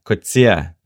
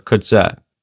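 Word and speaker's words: Coetzee: And just with the pronunciation, Sphinxy, it's Kutsia, not Kutsa.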